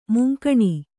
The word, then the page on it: ಮುಂಗಾಣು: mungāṇu